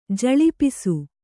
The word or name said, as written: ಝಳಪಿಸು